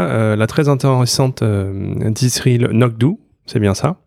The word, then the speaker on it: anCnoc: Et qui développe un single malt, alors qui s'écrit A-N-C-N-O-C, mais qui se prononce Ça se prononce donc Euh, Nokdu, donc, qui est la colline noire en gaélique, et Anok, qui est adossé à la colline, tout simplement.